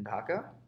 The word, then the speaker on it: Dhaka: Growing up in Dhaka, am I pronouncing that correctly? – Yeah, so Woo! My whole life, I basically lived in Dhaka.